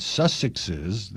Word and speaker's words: Sussexes: Instead, these self-pitying Sussexes, how do you pronounce that? Sussexes, there, that's better, will only feature behind the Netflix cameras.